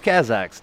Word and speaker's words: Kazakhs: And the song was called Five Hasog or Five Kazogs. – Five Kazakhs, dude. Kazakhstan. Kazakhs.